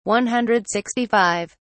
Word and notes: 165: British English

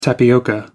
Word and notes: tapioca: American English

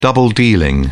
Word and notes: double-dealing: British English

